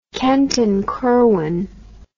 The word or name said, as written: Kent and Curwen